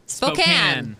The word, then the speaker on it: Spokane: And help me out here, Spokane? – Spokane. – Why don't they spell it S-P-O-C-A-N-E?